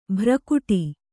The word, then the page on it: ಭ್ರಕುಟಿ: bhrakuṭi